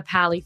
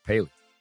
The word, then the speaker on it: Paley: Um, I'm working at the Paley Fest. Is that how you say it? Mm-hmm. Or is it the Pally Fest? – Paley.